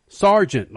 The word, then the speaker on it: Sargent: S-E-A-R? No, it's like it sounds, damn it. Sergeant, like Gregson. – Gregson. – I don't... How many Gregs do you know G-R-A-G? – It doesn't matter.